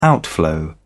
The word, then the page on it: outflow: British English